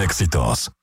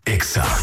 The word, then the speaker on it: Exa: No se dicen éxitos, se dice Exa. – En todas partes. – En todas partes. Ponte Exa FM. – 89.9. – Escuchas Wake Up en Exa FM.